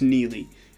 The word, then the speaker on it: Neagle: Well, because it's spelled N-E-A-G-L-E, and so I was calling her Neely. – Oh. – As opposed to dropping the I guess the G is silent. – The G is silent. It's just Neely.